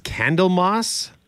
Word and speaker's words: Candlemas: is it pronounced Candlemas Day? – That's right. Yeah.